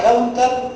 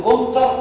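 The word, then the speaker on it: counter: ايوه كاونتر. ده اللي هو بيحط ايديهم عليه كاونتر. كاونتر ده اللي هو ترابيزه. في ناس يقولوا كونتر. كونتر ده خشب. نطقها مش كده خالص.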